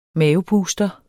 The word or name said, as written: mavepuster